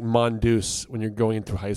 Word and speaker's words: Mondeuse: It's spelled Mondeuse.